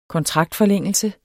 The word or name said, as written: kontraktforlængelse